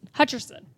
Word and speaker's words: Hutcherson: Hutcherson? – Sorry, take beep. – Hutchinson? – Three. – Hutcherson? – Two. – Hutcherson. – Hutcherson. – Josh Hutcherson.